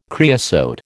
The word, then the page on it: creosote: American English